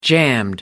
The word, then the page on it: jammed: American English